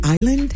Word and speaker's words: island: Ever wonder why the word island has a silent S?